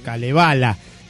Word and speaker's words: Kalevala: que está el Canteletar y el Kalevala, o Kalevala, como lo pronuncian los fineses, qué sé yo.